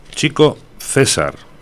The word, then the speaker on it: Chico César: Chico César, ¿eh? Con acento en la E.